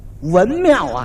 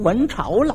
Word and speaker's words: 文庙: 一指这块匾，文庙啊，给念成文朝了，念错了一个。